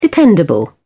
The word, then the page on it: dependable: British English